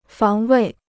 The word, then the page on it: 防卫: fáng wèi